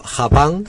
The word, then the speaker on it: Japan: está Japan, bien pronunciado, ya sabéis, Japan.